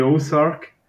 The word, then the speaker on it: Ozark: Ja, ich habe das Gefühl, dass die Serie mega viele Leute schon geschaut haben, wenn sie jetzt gleich einmal die Ozark.